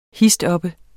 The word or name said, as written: histoppe